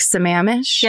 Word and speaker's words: Sammamish: And just the way, like the different things that went on, like, you know, and I want to say it's Lake Sammamish. – Yes. – Is how it's pronounced.